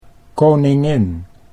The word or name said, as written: koningin